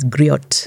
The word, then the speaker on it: griot: Or if you're funny, you can't pronounce things as griot. But it's griot. G-R-I-O-T.